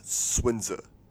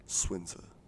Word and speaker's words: Sun Tzu: But anyways, he told me Sun Tzu, it should be pronounced Swinza. And it's always fucked me up ever since. – Yeah. – Swinza. Like, who wrote the letters S-U-N if it makes a swin sound?